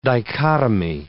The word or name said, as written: dichotomy